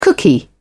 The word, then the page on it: cookie: British English